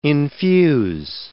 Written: infuse

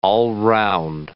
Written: all-round

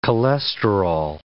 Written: cholesterol

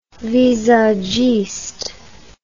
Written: visagiste

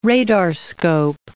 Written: radarscope

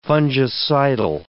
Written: fungicidal

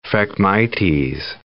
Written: phragmites

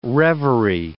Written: reverie

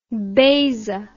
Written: beisa

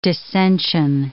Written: descension

